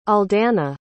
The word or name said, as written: aldana